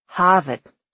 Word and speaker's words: Harvard: Harvard, the Monks called it, short A.